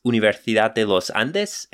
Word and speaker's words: Universidad de los Andes: I believe it's Universidad de los Andes. Am I saying that right? Yeah, uh, with a Spanish uh, accent, yes.